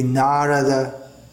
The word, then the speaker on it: Narada: Pronunciation which was very common previously, less so nowadays among English, native English speakers, Narada Muni, Devaki, should be Narada, Devaki.